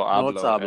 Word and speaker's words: hablo: No habla español. Don't try me. – It sounds like you even said that wrong. No hablo español. – Yeah, with an A.